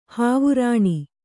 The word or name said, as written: ಹಾವರಣ